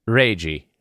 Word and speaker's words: Leiji: And so this part wasn't subtitled, so I didn't know what was being said, but this is it pronounced Leiji? – Reiji. – Reiji, and Matsumoto. – I mean, it stylizes Leiji. It's one of those things that, so there's no R or L in Japanese, so it's kind of like a Reiji, but it's, you know, however you want to say it.